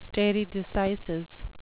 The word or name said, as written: stare decisis